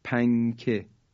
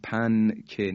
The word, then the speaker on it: پنکه: مثلا ما میگیم پنکه، هیچ موقع نمیگیم پنکه، نونشو تلفظ نمی کنیم، در حقیقت اخفا میکنیم.